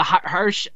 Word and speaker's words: Hirsch: Like yes, she took on legit Leyla Hirsch. Hirsch. I, I'm bad at pronunciation. Hirsch, right? – Yep, that is correct.